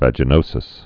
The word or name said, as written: vaginosis